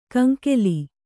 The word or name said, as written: ಕಂಗೋಲು